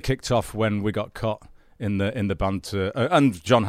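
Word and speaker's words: Cait: Her name looks like Kate, but it's actually pronounced Cot.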